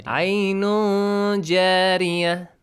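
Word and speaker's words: Ainun jari (عين جارية): So, how do you say this? Fiha ainun. Ainun. Is it correct? – No. – No. – No. – Ainun jari. – Ainun jari. That's correct.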